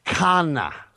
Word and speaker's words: Khanna: Khanna. – Okay. – Rajesh Khanna.